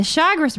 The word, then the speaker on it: Chagres: From the lake, the Chagres River, which is, did I say that right? Chagres? – Yes. – Chagres? – Chagres.